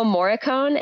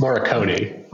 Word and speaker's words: Morricone: Ennio Morricone, am I saying that correctly? – It's Morricone. – Morricone, sorry.